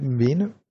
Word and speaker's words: Mwene: Bei euch spielen ja auch ein, zwei ehemalige vom VfB, die sich ja vor allem Philipp Mwene, spricht man den so aus, gell? Ja, doch.